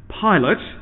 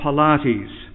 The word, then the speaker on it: Pilate: which is pronounced Pilate, not Pilates, as I heard it pronounced late last year by an enthusiastic young reader from one of our local schools. Pontius Pilates was apparently involved.